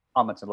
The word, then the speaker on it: Amundsen: Amundsen, like, oh, that's it. – Like the Amundsen Theater?